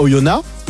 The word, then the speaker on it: Oyonnax: À côté de Bourg, il y a Oyonnax.